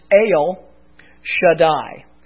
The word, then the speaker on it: El Shaddai: We said anytime you see the English translation God Almighty in your Bible, it is most times the Hebrew word El Shaddai. And there are the Hebrew letters so that you can all see. That would be pronounced El Shaddai.